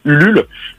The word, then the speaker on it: Ulule: une plateforme de financement participatif qui s'appelle Ulule comme ça se prononce, hein, U-L-U-L-E, euh, sur l'action Je ne rends pas mon tablier.